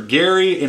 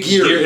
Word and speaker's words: Geri: Gary and Mr. G-E-R-I. Geary, maybe? – Geary. – It's probably Geary and Freki.